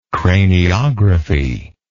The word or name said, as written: craniography